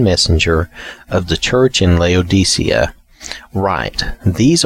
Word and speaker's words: Laodicea: Now, I was saying Laodicea, but from what I've seen and listened to and read, the pronunciation should probably be Laodicea. At least that's the accepted pronunciation from what I can tell.